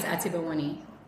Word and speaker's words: Atebawone: So my last name is Atebawone.